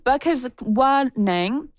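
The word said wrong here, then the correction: Bkejwanong: let me try that again, Bugis Nan Wing territory, also known as the Walpole Island First Nation in southern Ontario.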